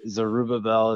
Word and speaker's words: Zerubbabel: Zerubbabel is usually how I say it.